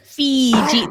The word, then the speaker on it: Feejee: And it was spelled F-E-E-J-E-E. So very phonetically. – Fiji. There was no not pronouncing it right.